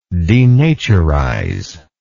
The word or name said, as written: denaturizer